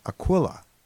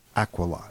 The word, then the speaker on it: Aquila: Now you might know this constellation as Aquila, but the correct pronunciation is actually Aquila.